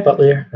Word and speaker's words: Buttliere: Yeah, Buttliere.